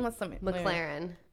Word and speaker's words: McLaren: McLaren.